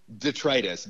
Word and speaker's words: detritus: D-E-T-R-I-T-U-S. You've probably read it, and you might have thought it was pronounced detritus, which is what I thought it was pronounced for a very long time. but it's pronounced detritus.